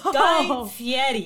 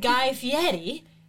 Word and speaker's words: Fieri: And it's not Guy Fieri. It's Guy Fieri.